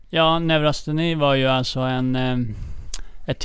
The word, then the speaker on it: neurosteni: Bland annat så skriver du om, nu ska vi se ifall jag uttalar det här rätt ens, neurosteni, amerikanska sjukan. Ja. – Eller vad säger man? – Neurosteni brukar man säga.